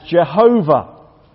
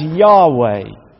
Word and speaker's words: Yahweh: For some time, it was translated as Jehovah, which was almost certainly wrong. It's now normally pronounced as Yahweh.